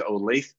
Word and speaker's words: Olathe: I don't know how to pronounce it. I've always said it's Olathe. Olathe. – Yeah.